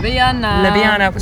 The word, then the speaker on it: Ljubljana: Ljubljana.